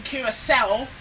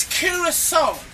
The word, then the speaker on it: Curaçao: I mean, I have been a little bit frustrated that some of the punters have been saying Curaçao. It's Curaçao. – Yeah. – Yeah? That is the correct pronunciation.